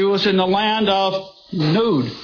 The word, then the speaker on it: Nod: In Hebrew, it's not Nod, it's Nod. It's pronounced long O.